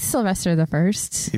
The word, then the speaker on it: Sylvester I: Or Pope Sylvester I? How do you say that? – I think it's Sylvester the First.